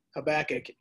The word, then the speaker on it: Habakkuk: But honestly, I just always said Habakkuk. And this is maybe that's a kind of a Midwestern Chicago thing where we accent the middle syllable, but it's just the way it is, Habakkuk.